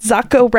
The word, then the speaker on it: Zacco: She's like, Zacco rhymes with taco.